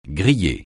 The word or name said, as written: griller